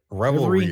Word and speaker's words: Revelry: What's this? Riveri? I think I'm saying that right. – Revelry is the word.